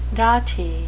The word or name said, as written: dotty